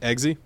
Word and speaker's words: Eggsy: I liked Iggy better. – Eggsy.